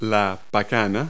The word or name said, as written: pecan nut